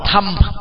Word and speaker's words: Dhamma: Có khi người ta là Dhamma, mà nếu mà đọc gọn là thâm, tại vì a ở cuối từ cũng là a câm, thâm thâm.